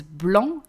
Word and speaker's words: blanc: In French, you wouldn't, because blanc actually is a word. It means white. It is spelled B-L-I-N-C, but we do not pronounce the letter C at the end because it's a final consonant and we do not pronounce it.